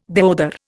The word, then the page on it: deodar: Indian